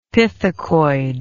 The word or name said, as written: pithecoid